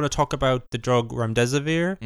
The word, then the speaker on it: Remdesivir: Your headline story put some uh, put some light on the Ramdab Ramdamavizir. – I'm Remdesivir.